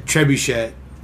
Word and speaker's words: trebuchet: Or is it trebuchet? But the T is silent at the end in a French word, so it's trebuchet.